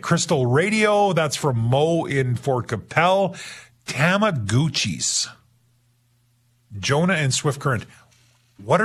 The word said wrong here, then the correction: Tamagotchi: Tamagotchi, I'm saying. Okay, so they're Tamagotchi. I'm saying it wrong. Tamagotchi, okay.